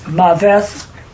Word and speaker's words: maveth: But death, the Hebrew word for death, is maveth, which is a noun. – Can you spell that, please? – M-A-V-E-T-H. So it's pronounced M-A-W-V-E-T-H, which is a noun and comes from muth, which is the verb.